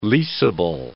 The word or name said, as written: leasable